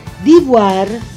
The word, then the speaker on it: Divoir: Divoir. Si scrive D-I-V-O-I-R, ecco. – Divoire, sì. – È perché è francese, quindi si pronuncia or, ma si scrive ire.